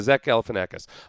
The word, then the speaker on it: Zach Galifianakis: Is that how you pronounce his name? Zach Galifianakis? Yeah, Zach Galifianakis.